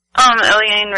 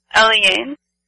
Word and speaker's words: Eliane: Say with the name again? – Eliane Radigue. I really enjoy her drone works. – E-L-I-A-N? – Eliane. – How do you spell it? – E-L-I-A-N-E.